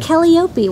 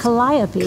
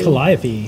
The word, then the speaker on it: Calliope: It's not Calliope, which I thought. It's Calliope. – Calliope.